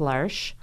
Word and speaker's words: L'Arche: So it looks like L'Arche, but it's pronounced L'Arche, and the website is LARCHEUSA.